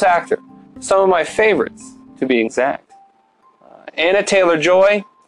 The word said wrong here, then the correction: Anya Taylor-Joy: our Anna, I want to make sure I pronounce it right, but I think it's Anya Taylor-Joy, is fantastic as the main character.